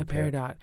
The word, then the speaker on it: peridot: I don't know if I'm saying this right. Peridot? – Yeah, peridot. – Or peridot.